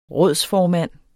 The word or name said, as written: rådsformand